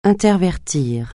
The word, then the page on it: intervertir: francais